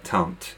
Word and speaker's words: Tante: Are we not going with the French pronunciation of auntie, Tante Go? – Yes, sorry, that's just me being bad. So Tante, yes, good.